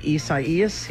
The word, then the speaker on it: Isaias: But it looks like Isaiah. – It looks like Isaias, and that's how everybody's been pronouncing it.